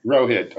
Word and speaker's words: Rohit: Rohit, okay.